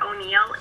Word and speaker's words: O'Neill: Yes, Heather O'Neill, H-E-A-T-H-E-R-O-N-E-I-L-L.